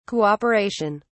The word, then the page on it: Cooperation: British English